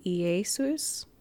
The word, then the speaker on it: Iesus: Um, the Greek pronunciation would be Iesus, and Latin changed that to y- y- the Y, well, the I. the I changed to Y, changed to J, right?